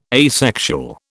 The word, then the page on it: asexual: British English